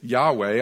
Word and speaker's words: Yahweh: So years ago, the Jewish people inserted the vowels from Adonai and pronounced it Yahweh.